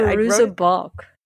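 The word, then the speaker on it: Fairuza Balk: But it's Faruqa Balt. However you say her name. How do you say it? – Faruza Balk.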